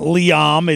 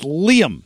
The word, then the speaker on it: Liam: It's not Liam, it's Liam, apparently. – Apparently. I mean, no one would see L-I-A-M and say Liam.